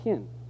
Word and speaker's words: chen: Well, the Hebrew word for grace in the Old Testament is called chen. It's spelled C-H-E-N, chen.